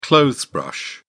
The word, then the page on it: clothes brush: British English